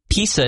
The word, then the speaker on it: PISA: It is PISA is how you say it.